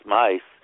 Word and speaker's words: Myss: Carolyn Mice. Give me that name? Carolyn Mice. How do you spell that last name? – M-Y-S-S. – M-Y-S-S.